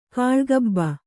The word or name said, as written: ಕಾಳ್ಗಬ್ಬ